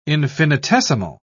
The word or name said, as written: infinitesimal